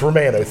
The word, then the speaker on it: Romano: It's Romano.